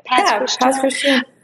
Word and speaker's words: Pass Christian: Pass Christian.